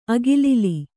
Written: ಅಗಿಲಿಲಿ

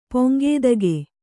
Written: ಪಂಕ್ತಿಕೆ

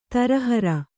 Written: ತರಹರ

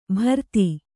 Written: ಭರತಿ

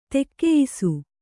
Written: ತಕ್ಕೆಯ್ಸು

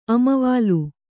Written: ಅಮವಾಲು

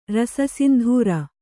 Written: ರಸಸಿಂಧೂರ

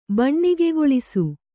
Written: ಬಣ್ಣಿಗೆಗೊಳಿಸು

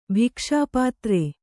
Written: ಭಿಕ್ಷಾಪಾತ್ರೆ

